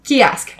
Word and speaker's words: Ceasg: And I found an actual pronunciation guide for that one, because that is spelled C-E-A-S-G. Kiosk.